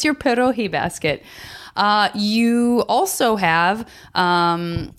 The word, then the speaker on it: pierogi: Pierogi? – Pierogi.